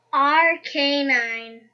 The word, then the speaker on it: Arcanine: Okay, so how do you pronounce this one? – It is pronounced Arcanine. Mm-hmm.